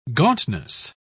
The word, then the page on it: gauntness: British English